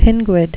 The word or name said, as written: pinguid